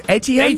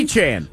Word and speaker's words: Etienne: Hey, speaking of New Orleans, it's pronounced Etienne? – Etienne. – Like Etouffee? – Like the one in...